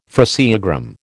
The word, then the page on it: phraseogram: American English